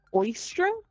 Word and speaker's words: Eostre: old pagan tradition called Oistra. And granted, I don't have the right accent. I'm horrible at accents. But it's spelled E-O-S-T-R-E.